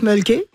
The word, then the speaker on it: Mölkky: On prononce Mölkky. – Ouais. – Et c'est M-O-L-K-K-Y.